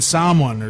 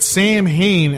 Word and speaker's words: Samhain: That's someone who I don't know, somebody fucking told me that, but Samhain, as us filthy Americans pronounce it, it's Samhain in Irish, in Gaelic.